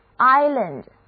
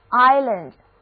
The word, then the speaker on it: island: 注意这里的字母 s 不发音，而字母 i 要发它的本音 i。注意后面的 land 不再念作 land，因为字母 a 的读音是 a。整个单词合起来 island island。